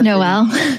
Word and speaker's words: Noel: I'm hoping I'm saying that correctly. Block. – It is Noel.